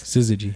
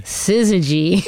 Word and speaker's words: Syzygy: Syzygy. – Syzygy.